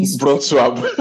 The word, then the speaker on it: Wrocław: How is that city pronounced again? I really Wrocław. Wrocław, yeah.